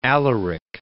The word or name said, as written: Alaric